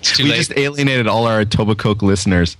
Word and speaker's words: Etobicoke: Do you pronounce the K in Etobicoke? – You do. – That was Etobicoke. – If you pronounce the K, then you are not from this area.